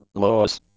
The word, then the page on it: loess: Canadian English